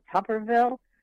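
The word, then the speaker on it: Tuberville: And the other thing that I've heard is that, forgive me if I mispronounce his name, is it Tupperville? – Yeah.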